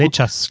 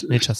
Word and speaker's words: Nečas: Und wenn man auf jeden Fall sich angucken darf, Martin Nečas, mal schauen, ob das, bin ich so der Tscheche, richtig ausgesprochen. – Nečas, glaube ich, Nečas. – Ja, Nečas, Dankeschön.